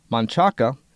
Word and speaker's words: Manchaca: In the south part of town, Manchaca, named after a captain in the Texas Army who spelled the first syllable with an E instead of an A, is pronounced Manchac.